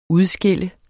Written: udskille

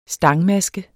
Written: stangmaske

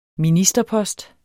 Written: ministerpost

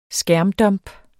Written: skærmdump

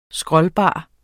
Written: scrollbar

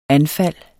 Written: anfald

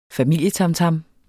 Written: familietamtam

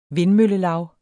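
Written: vindmøllelav

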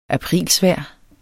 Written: aprilsvejr

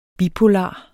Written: bipolar

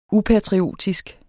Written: upatriotisk

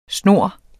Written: snur

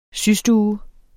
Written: systue

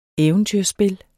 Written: eventyrspil

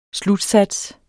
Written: slutsats

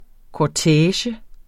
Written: kortege

